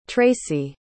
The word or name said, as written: traci